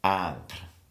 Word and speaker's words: Ingres: I N G R E S, but Kenneth Clark pronounced it Angre, like that.